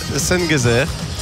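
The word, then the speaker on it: Şengezer: Et alors que sur le banc, on a le numéro 24, Mohamed Sengezer. Ouais. Ça se prononce comme Ebenezer, le Z.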